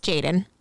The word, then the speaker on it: JXDN: It's JXDN, but it's pronounced Jaden.